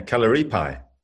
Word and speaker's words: Kalaripayat: You're spreading the word of Kalaripayat. I don't even know if I could say it properly. – You said it right. – Yeah, Kalaripayat.